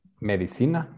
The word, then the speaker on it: medicina: Medicino. Am I saying that right? – Medicina, está bien.